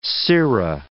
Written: sirrah

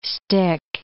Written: stick

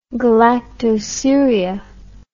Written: galactosuria

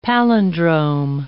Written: palindrome

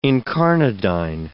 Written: incarnadine

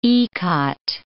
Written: ikat

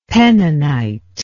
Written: penninite

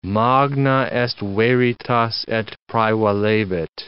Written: magna est veritas et praevalebit

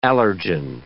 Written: allergen

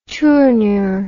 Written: tournure

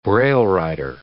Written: braillewriter

